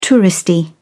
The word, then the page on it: touristy: British English